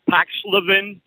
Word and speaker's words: Paxlovid: Paxlovid.